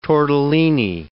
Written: tortellini

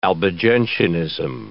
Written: Albigensianism

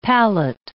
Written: palette

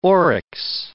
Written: oryx

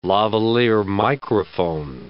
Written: lavalier microphone

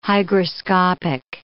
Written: hygroscopic

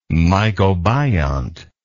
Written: mycobiont

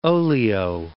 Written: olio

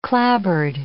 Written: clapboard

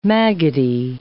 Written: maggoty

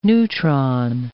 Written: neutron

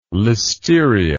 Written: listeria

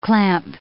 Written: clamp

